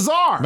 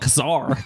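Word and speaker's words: Czar: Why is there a C in front of Tsar? – Because it's silent.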